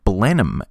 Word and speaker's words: Blenheim: Its name is more commonly pronounced in English as Blenheim,